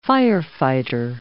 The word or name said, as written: firefighter